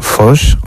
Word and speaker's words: Fosse: de John Foss, o Fosse, que es como se escribe, premio Nobel, que está representado por la Escuela Municipal de Teatro de Burgos en su 37 promoción.